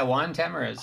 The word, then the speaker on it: Tamariz: Yeah, yeah, Juan Tamariz.